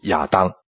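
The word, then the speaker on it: 亚 当， (אדם): 的确是的，有灵的活人是我们中文圣经的翻译，在原文就是活的人，希伯来文的发音就是亚当，所以我们中文圣经就在这里加上一句说名叫亚当。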